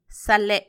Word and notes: salhe: Macuiltianguis

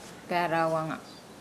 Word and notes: gaalauanga: Nukuoro

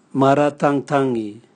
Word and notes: maratangtangi: Raga

